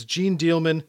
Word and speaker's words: Jeanne Dielman: And I'm going to do the English pronunciation because I don't care. It's Gene Dielman, 23 Commerce Quay, 1080 Brussels. – Oui, je l'ai vu plus ou moins, Jeanne Dielman.